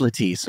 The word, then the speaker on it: Lettice: Her name is spelled L-E-T-T-I-C-E, so it's Lettice.